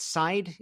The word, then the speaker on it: Seid: So before we discuss Marble Freedom Trust and this it's Seid, is that how you pronounce his name? – Yes, or Barre Seid.